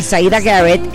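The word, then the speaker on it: Siedah Garrett: Así es, y bueno, ya extrañaba la pronunciación perfecta que tienes, porque sí es Zaida Garrett, tienes toda la razón.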